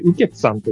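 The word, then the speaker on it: 雨穴: 漢字で、雨の穴と書いて、ウケツさんという方。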